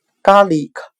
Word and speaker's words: garlic: 我们可以用谐音法来记忆这个单词 garlic，它的发音很像汉汉语的咖喱可，咖喱是指。